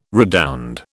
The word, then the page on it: redound: British English